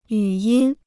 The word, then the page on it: 语音: yǔ yīn